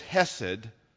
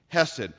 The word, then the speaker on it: chesed: In the Old Testament, the word in the Hebrew is hesed, it's spelled C-H-E-S-E-D, hesed.